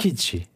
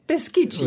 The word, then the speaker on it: Peschici: Peschici. – Peschici, oui.